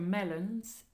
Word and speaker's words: melon: And the French for melons is the same word... – Melon. – But a slightly different pronunciation.